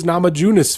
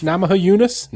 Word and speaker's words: Namajunas: He writes, I came into Tuff being a big Rose Namajunas fan. Did I say that right? – No, not at all. Namajunas? – Namajunas. Nailed it.